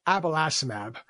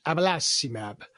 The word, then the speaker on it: abelacimab: It's called abelacimab, or we like to say with an Italian version, abalacimab.